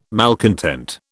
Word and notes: malcontent: British English

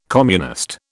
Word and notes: communist: British English

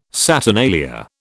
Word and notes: saturnalia: British English

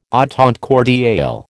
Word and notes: entente cordiale: American English

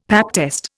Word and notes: pap test: Indian

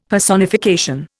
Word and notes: Personification: Indian

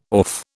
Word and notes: orfe: British English

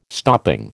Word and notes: stopping: American English

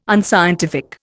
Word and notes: unscientific: Indian

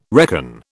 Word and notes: reckon: British English

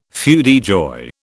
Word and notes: feu de joie: British English

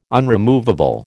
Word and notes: unremovable: American English

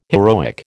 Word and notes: heroic: American English

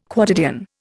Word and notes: quotidian: Indian

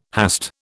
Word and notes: hast: British English

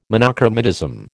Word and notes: monochromatism: American English

